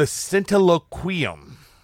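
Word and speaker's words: Centiloquium: All right, so next it goes on to talk about some aphorisms from a book called The Scintilloquium. I think I said that right. – Okay. – I did say that right.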